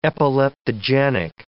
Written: epileptogenic

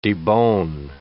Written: debone

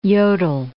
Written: Jodl

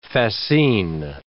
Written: fascine

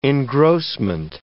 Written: engrossment

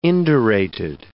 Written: indurated

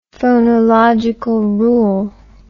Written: phonological rule

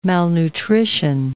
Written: malnutrition

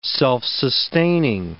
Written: self-sustaining

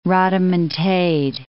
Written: rodomontade

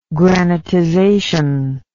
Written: granitization

